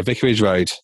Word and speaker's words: Vicarage Road: Yeah, so with Vicarage Road, am I pronouncing that right, or are there too many? – That is correct, yeah. Vicarage Road, yes.